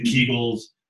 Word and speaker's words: Kegel: So, and can I just, I think it's actually pronounced Kegel. – Kegel. – I know, it's like bagel, Kegel.